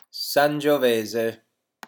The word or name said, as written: sangiovese